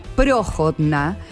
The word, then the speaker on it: Prohodna: Se escribe Prohodna, pero se pronuncia Prohodna.